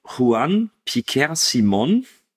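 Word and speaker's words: Juan Piquer Simón: Gedreht wurde der Film von Juan Piquer Simon, würde ich jetzt mal behaupten, dass es so ausgesprochen wird. – Ja, ist richtig.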